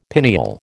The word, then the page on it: pineal: American English